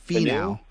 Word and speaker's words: Finau: I'm taking the group of Fleetwood, DJ, and can I get a pronunciation of Tony? Is it Finau? – Finau. – Finau. Finau. Yeah. Thank you.